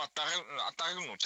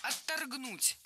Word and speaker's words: отторгнуть: По крайней мере, там достаточно было показано того, что должно отторгнуть от этой, от такого, от какой карьеры в сфере древнейшей профессии. – Отторгнуть неверно, правильно отторгнуть. Ударение в этом слове ставим на второй слог.